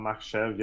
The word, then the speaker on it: Makhachev: Islam Makhachev, yeah.